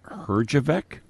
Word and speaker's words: Herjavec: Kim Johnson and Robert Herjavec.